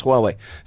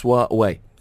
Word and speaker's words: Huawei: Somebody's just texting in saying, learn how to pronounce Huawei. It's not a three-syllable word. It's Huawei. Huawei? – Okay, so the first time that I went to one of their presentations, the guy who was here from Huawei in China, he said, simply think of it as, who are we?